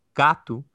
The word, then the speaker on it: gato: And this cat, gato, ends with an O, even though I'm pronouncing it a bit with an oo sound.